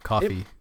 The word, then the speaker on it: Ko-fi: I think that's pronounced coffee. – We've had this discussion. – Because that's what I said. – I think it should be because it's like, oh, buy me a coffee.